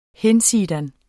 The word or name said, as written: hinsidan